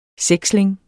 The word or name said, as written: seksling